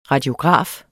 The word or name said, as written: radiograf